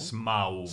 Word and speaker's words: Smaug: Ale v tom originále, nebo v tom, v tom anglickém, já teda jsem stihnul zapomenout, jak se, vyslovuje, jak se vyslovuje šmak v angličtině. Smog? – Smaug? Smaug. Smaug tak i, ale i jak je to napsané, tak to rozhodně jako není jako eh, dráček šmáček.